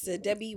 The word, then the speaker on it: Debbie: Debbie Romeo on the places where I want to be found. – Okay. Mm-hmm. – And it's Debbie with a double B-I-E.